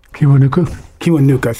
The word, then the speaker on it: Kiwanuka: Like, I'm not going to get his name right, but Michael Kiwanuka, he's the British soul singer. – Kiwanuka, thank you.